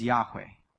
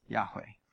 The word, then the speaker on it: Yahweh: And the best pronunciation we've got in English is Yahweh. Yahweh.